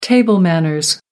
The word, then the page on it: table manners: American English